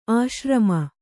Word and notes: ಆಶ್ರಮಿ: āśrami

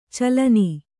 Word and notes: ಚಲ್ಲೆಣ್ಣೆ: kannada; calleṇṇe

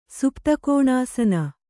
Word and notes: ಸುಪ್ತಕೋಣಾಸನ: Kannada

